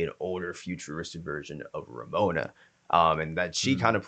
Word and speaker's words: Ramona: And now towards the end, we're now starting to see Ravonna have to now almost, am I saying her name right? I'm saying Ravonna. Ramona. I'm saying V. Yeah, Ramona.